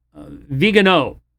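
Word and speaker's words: Viganò: Vigano is how some people say that it's supposed to be pronounced.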